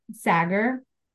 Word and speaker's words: Sager: So first and foremost, when it comes to a thriller, I think you, Andrew, should read any Riley Sager book and any Taylor Adams book. – Riley who? – Sager. – S-A-G-E-R.